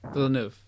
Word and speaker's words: Villeneuve: Uh, I can never pronounce his last name. Villeneuve. – Villeneuve. Yeah, it's French, I believe.